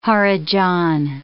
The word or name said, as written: harijan